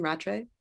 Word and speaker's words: Rattray: But I want to talk about that David Rattray, is that how you say his name, Rattray? – Yeah.